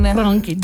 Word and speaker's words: Frankenstein: So this show is about Frederick Frankenstein. – Frankenstein. – Thank you for pronouncing it correctly.